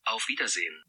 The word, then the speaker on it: Auf Wiedersehen: In German, goodbye is Auf Wiedersehen. Duh. – Auf Wiedersehen.